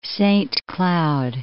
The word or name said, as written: Saint Cloud